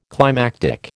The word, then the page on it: climactic: American English